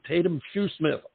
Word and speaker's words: Taydem Shoesmith: She goes by the name of Taydem Shoesmith.